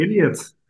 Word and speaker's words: Iliad: I think the Iliad. – Yeah, the Iliad.